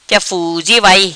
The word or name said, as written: Ja Fuzi Vai